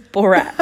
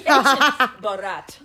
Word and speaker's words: Barat: His name is Barat. – Barat. – He obviously, yeah. – His name is Borat. – Borat, yeah. – Like the French spelling, French pronunciation, Borat. – It's B-A-R-A-T, Borat.